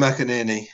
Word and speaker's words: McInerney: What about another name I can't pronounce, Tim McKinney? Or is that right? Go on, correct me again. – Tim McInerney. Yeah.